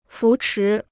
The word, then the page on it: 扶持: fú chí